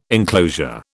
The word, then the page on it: enclosure: British English